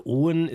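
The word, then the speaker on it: Aaen: Also äh, Owen ist ein Familienname. Dänisch spricht man Doppel A wie O aus und, und deshalb eigentlich sagen die Dänen, sogar Daumaohn.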